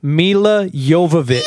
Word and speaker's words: Jovovich: From her own beautiful lips, it's pronounced Mila Jovovich.